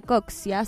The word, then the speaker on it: Coccia: Me pueden ir a seguir, @ Camila Coxia, COCCIA.